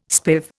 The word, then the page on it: spiv: Indian